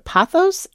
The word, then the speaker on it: Pothos: Patrick writes, this is a photo of me at grandma's house, the late 1990s, with her, so am I pronouncing this right? Pothos in the corner? – Yes.